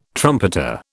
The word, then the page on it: trumpeter: British English